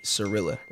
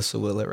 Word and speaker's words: Siirila: So that's kind of cool because, so my last name is Siirila, right? I'm sorry. So I got to bring this up. Like, so my name, Killa Siirila, right?